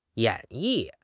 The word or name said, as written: 演绎